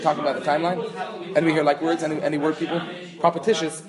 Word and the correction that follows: propitious: means time, and the time is Masugal. – Propitious, sorry.